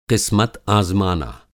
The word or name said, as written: क़िस्मत आज़माना